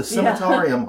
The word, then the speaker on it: Cemetarium: I had to actually look up how to pronounce that. The Cemetarium Haunt. Be very careful with that pronunciation, or else it sounds way worse than this.